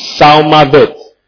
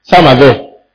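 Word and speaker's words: Salmaveth (צלמות): It's T sound. The T silence. The T silence used to be S straight. Okay. T-S-A-L. You have to spell it something like that. T-S-A-L-M-A-V-E. T-S-A-L-M-A-V-E-T-H. Salmaveth. Salmaveth.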